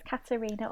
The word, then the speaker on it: Katerina: Yeah, it's Katerina, or you can call me Katie.